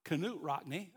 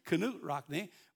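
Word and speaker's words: Knute Rockne: Uh, hey, I don't know how many of you know the story of Newt Rock, Canute Rockney. I didn't realize that's the way you pronounce it, Canute Rockney.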